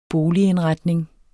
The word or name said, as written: boligindretning